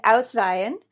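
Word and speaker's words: uitwaaien: Yeah, so that would be an Uitweeën.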